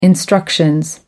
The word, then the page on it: instructions: British English